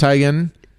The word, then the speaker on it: Teigen: And yes, it's Teigen.